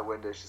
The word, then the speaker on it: Windisch: That's my Twitter is my favorite thing, at Ty Windisch. It's spelled with an I, T-I-W-I-N-D-I-S-C-H.